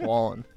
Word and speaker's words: Wallen: Isn't it Wallen? – I don't know. Whalen, I say Whalen. – I think it's Wallen. – Is it Wallen? – Yeah.